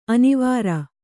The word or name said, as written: ಅನಾವರ